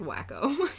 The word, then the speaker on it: Waco: It's pronounced Waco.